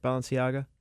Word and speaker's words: Balenciaga: Balenciaga? – Yep. – I mostly know that because of the Lizzo song. – I was just going to say in my Balenciasis. – Yeah. – Yeah. – Balenciaga is selling women's granny panties for $225 a pair.